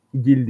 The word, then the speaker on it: Gil: Aslında Gil diye okunuyor İspanyolca'da ama şimdi aksan kattığımda insanların dalga geçeceğinden korktuğum için Gil diyeceğim.